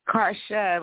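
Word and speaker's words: Carsha: Karsha, like Marsha, but with a C.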